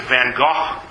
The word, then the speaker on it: van Gogh: If you're in Europe, you don't pronounce it van Gogh, you pronounce it van Gogh. But I don't think I can pronounce it that way very long because it sounds odd to my ears.